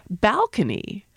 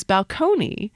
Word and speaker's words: balcony: But when it was borrowed in from Italian, it was balcony. So when the stress moved forward to the first syllable, that was enough to upset people.